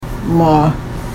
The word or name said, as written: wmaa